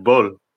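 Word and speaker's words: Bølle: Bull. I actually looked it up and did it a deal a while ago. It's Bull. – Okay, so there's no A at the end. Okay. It's B-O-L-L-E with the O with the slash in it.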